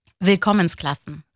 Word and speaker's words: Willkommensklassen: telaffuzunu söyleyeyim bana. – Willkommensklassen. – Ha, Willkommensklassen. Evet, benimki olmamış, evet.